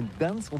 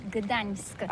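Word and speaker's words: Gdańsk: C'est pourquoi on dit Gdansk.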